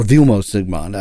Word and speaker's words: Vilmos Zsigmond: And you know, you mentioned Vilo Sigmund, and honestly, or Vilmos Zsigmond, I always get his name wrong,